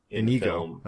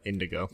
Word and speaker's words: Inigo: Mandy Patinkin as fucking Inigo Montoya, which is one of the best character names. – Inigo, not Indigo.